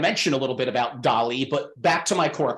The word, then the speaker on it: DALL-E: How might the interface of ChatGPT and DALL-E, we didn't even talk about that, that is D-A-L-L-E. Yes, E. So, but it's pronounced DALL-E, by the way.